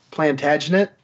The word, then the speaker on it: Plantagenet: Edward Longshanks was, and I always screw up how you pronounce this, but it's Plantagenet.